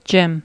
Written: gym

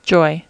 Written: joy

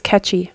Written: catchy

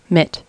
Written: mitt

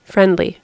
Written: friendly

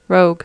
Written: rogue

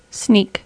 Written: sneak

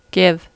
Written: give

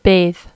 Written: bathe

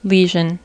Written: lesion

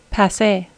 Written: passe

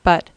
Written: but

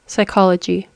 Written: psychology

